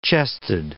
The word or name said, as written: chested